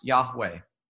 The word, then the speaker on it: Yahweh: God said to Moses, I am who I am, which in Hebrew is pronounced Yahweh. It's a breathe in and a breathe out.